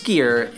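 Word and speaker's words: Skyr: Skier, just so you know, it is pronounced Skier, is an Icelandic culture dairy product.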